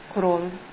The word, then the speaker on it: Kroll: My name is Elizabeth Kroll. I am the mother of Christiane Guti. – Okay. – Elizabeth. Yes, ma'am. Kroll. K-R-O-L-L.